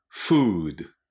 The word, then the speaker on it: food: food. food. 食べ物ね。これ問題ないと思いますが、発音がね、 food って言っちゃダメです。 food って止めてください。 food. do にならないように気をつけ、最後がね、 do で止まりますからね。